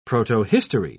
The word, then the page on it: protohistory: British English